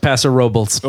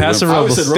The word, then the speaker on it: Paso Robles: The town is pronounced Paso Robles. – Oh, really? – Yeah. – Robles. They went Robles. – Yep. – Huh? – Ooh, it's Paso Robles.